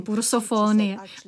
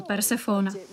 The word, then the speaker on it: Persefona: Takže já ten další den jsem četla a říkám Persefona, ne, myslím Pursofonie. A učitelka říká, ne, ty máš pravdu, vyslovuje se to Persefona.